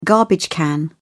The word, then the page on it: garbage can: British English